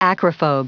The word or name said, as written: acrophobe